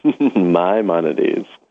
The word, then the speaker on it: Maimonides: Maimonides. Did I say it right? – Maimonides. – Okay, it took me a minute, but I got it.